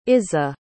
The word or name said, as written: izza